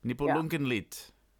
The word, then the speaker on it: Nibelungenlied: Nibelungenlied. – Genau. – Those pesky D's. – Perfekte Deutsch. – It's so just like, well, actually the D is a T. It's pronounced a T.